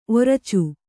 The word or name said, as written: ಒರಚು